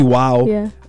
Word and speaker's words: Iwao: So, in lieu of Pi Day, Google announced today that an employee of Google, Emma Haruka Iwao, I think, is that how you pronounce Iwao? – It? – Iwao. Yeah. – Missed a W there.